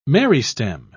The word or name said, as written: meristem